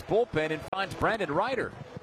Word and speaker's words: Reiter: Opponents hit 238 against the left-hander Brandon Ryder. Ryder is spelled R-E-I-T-E-R, Ryder.